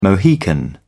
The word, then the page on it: mohican: British English